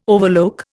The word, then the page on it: overlook: Indian